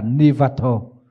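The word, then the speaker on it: nivāto: trong kinh đọc là nivato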